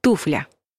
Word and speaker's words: туфля: Ну и сразу скажем про ударение. Никакого слова «туфля», только «туфля».